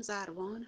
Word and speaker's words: زروان: هم زروان هم زربان، فرقی نمی‌کنه، تلفظش هر دو درست.